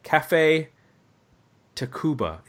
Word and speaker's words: Café Tacuba: Tell me one thing you like about Ray by Café Tacuba.